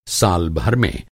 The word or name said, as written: साल